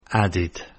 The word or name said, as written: added